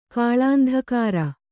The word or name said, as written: ಕಾಳಾಂಧಕಾರ